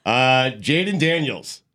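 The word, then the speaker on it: Jayden Daniels: Jaden Daniels. Did I say it right? – Yes.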